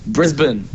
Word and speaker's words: Brisbane: Brisbane.